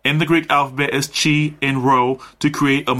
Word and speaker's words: chi: In the Greek language, the alphabet, the letter that looks like an X is Greek letter for C-H-I, pronounced chi. It rhymes with I, which is the first letter of the Greek word for Christ.